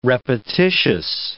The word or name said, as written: repetitious